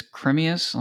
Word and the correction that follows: Timaeus: Let me get there. It's called Timaeus.